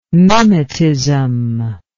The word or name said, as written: mimetism